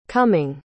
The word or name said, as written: cumming